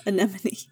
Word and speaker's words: anemone: Oh yeah, we did see, and we actually learned that there were several types of anemone fish. Is that how you pronounce it? – Yes, anemone.